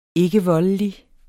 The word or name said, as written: ikkevoldelig